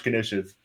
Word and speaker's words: knish: You might be able to process why this is, but I believe it's pronounced Rap Snitch Niches, perhaps, with Mr. Fantastic. – Knish. – Thank you. Knish. – It's Jewish. – Yeah, yes. Knish, yeah. That is right.